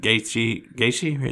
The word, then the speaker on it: Gaethje: Yeah, because, Because obviously, because I'm guessing Gaethje, is that how you say it? – Yeah.